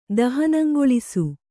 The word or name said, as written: ದಹನಂಗೊಳಿಸು